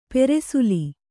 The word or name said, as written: ಪಾರ್ಸಲ್ಲು